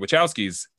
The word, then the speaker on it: Wachowskis: Yeah, the Wachowskis, they were the first ones where I'm like, well, I'm going to pay attention to them.